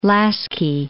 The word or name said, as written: Laski